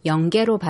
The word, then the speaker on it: 영계: 반가워가 반가워로 소리나는 것처럼 니은은 기역 앞에서 이응으로 소리나는데요. 이런 자음동화 현상에 따라 연계가 영계로 발음된 건데 오랜 세월을 거치면서 표기도 영계로 굳어진 겁니다.